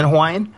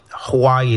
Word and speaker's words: Hawaii: A lot of the W's are actually V in Hawaiian. So, like, Hawaii is not actually Hawaii. It's Hawaii. – Really? – And same like the town that I'm from, that I pronounce as Haleiwa, it's spelt like Haleiwa. But if the W is in the middle of the word, it's actually a V, kind of like Wiesbaden or things like that in German.